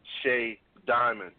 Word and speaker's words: Shaydymond: It's called Shay Diamond, and it's spelled S-H-A-Y-D-Y-M-O-N-D, Shay Diamond.